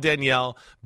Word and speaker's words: Daniel: It's spelled Daniel, you know, but it's called Danielle.